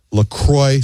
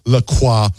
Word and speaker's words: La Croix: Officially, it's pronounced La Croix, so it rhymes with enjoy, mm-hmm. not La Croix.